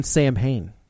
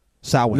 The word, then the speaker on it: Samhain: I think you mean Samhain. – Samhain. – No, I know. – It's actually pronounced.